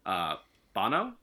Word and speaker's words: Bono: Bono? – Mm-hmm.